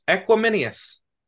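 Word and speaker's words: Equanimeous: Equiminius.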